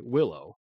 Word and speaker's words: Willow: you do go by Willow. – Yes. Like the tree. Yeah. As we were talking about, I tell people the G is silent. It stands for Gwendolyn, which was a very long thing to call a small child.